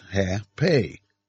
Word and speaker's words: J.P.: My initials are J.P. In Spanish, they are pronounced Ha-Pe, and my Mexican friends called me so when we were in Mexico.